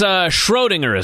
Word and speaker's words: Schrödinger: Now, it's Schrödinger. Is that how you pronounce it, Schrödinger's cat? – That's how I pronounce it.